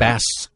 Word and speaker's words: Bas: Well, why are you pronouncing it Boz, though? – Right. – Probably like Baz or something. – Exactly. – Like Bass. – B-A-S, right? – Bass Rutten. – Boz would connote a Z, right? – Right, yeah.